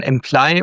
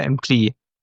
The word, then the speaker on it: Employ: Helft mir nochmal, wie wird euer cloudbasiertes HR-Management ausgesprochen? Employ oder Employee? – Employ heißt es ja. Ungefähr Employ. – wie Employee und dann nimmst die O weg und dann Employ.